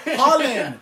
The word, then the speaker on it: Haaland: Oh, Haaland.